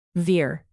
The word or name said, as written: veer